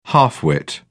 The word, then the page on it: half wit: British English